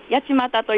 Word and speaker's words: 八街: そうです、うん、千葉県産の半立ちという落花生で、千葉県の八に、あの商店街の街、町と書いて八街と読むんですけども、落花生の中でも王様と呼ばれていて、とても甘くていい香りがするんですよね。